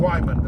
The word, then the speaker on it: Wyman: Also, uh, was uh, Bill uh, uh, Wyman. Wyman. That's how I pronounce it.